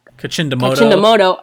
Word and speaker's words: Kachindamoto: we have Chief Teresa Kachimado. – Kachindamoto. – Oh, I always say it wrong.